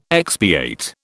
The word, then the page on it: expiate: British English